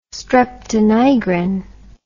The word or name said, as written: streptonigrin